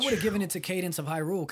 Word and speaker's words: Cadence of Hyrule: So we got Candace of how do you say Cadence of Hyrule. – Cadence of Hyrule, Devil May Cry 5, Kingdom Hearts 3 again.